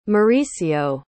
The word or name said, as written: Mauricio